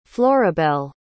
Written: florabel

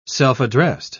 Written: self-addressed

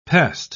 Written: pest

